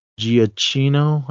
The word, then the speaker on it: Giacchino: This was directed by Michael Giacchino. I think that's how you say his name. – Yep, and, you got it.